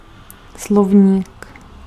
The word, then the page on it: dictionary: [ˈdɪk.ʃə.ˌnɛɹ.i]; [ˈdɪk.ʃən.ɹi]; /ˈdɪkʃ(ə)n(ə)ɹɪ/; /ˈdɪkʃənɛɹi/